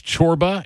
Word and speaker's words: Csorba: because it is C-S-O-R-B-A, pronounced Chorba.